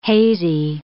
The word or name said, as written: hazy